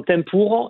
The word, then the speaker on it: Tempora: Alors ça se prononce exactement Tempour, et effectivement c'est le titre de l'album, alors ça veut aussi bien dire saison, moment propice en fait.